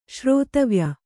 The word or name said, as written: ಶ್ರೋತವ್ಯ